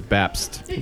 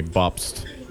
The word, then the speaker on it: Bapst: My last name is Bapst. – Robert Bapst. – Pronounced in Germany, Bapst.